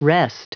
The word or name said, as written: wrest